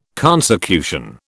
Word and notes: consecution: British English

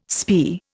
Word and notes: spae: Indian

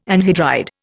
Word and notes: anhydride: Indian

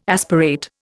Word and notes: aspirate: Indian

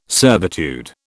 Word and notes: servitude: British English

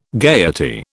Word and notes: gayety: British English

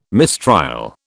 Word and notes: mistrial: British English

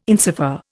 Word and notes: insofar: Indian

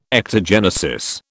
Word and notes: ectogenesis: British English

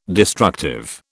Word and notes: destructive: British English

